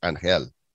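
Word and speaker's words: Ángel: It's spelled Angel, but it's pronounced Angel. – Yeah, yeah. – Because that's how you do things here in Espanol.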